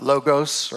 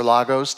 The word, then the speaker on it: logos: You know, we know the one logos or lagos, depending on how you want to pronounce it. And I've heard Greek scholars pronounce it both ways, so go figure.